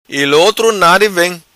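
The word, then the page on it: Vem Ilotra Nadi: patua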